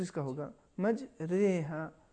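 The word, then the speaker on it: مجراها: لہٰذا اس اگر کوئی تلفظ اس طریقے سے کرے مج را ہا, یا اگر کہیں مج ری ہا, یہ دونوں تلفظ صحیح نہیں ہے بلکہ درمیانی کیفیت. صحیح تلفظ مج رے ہا.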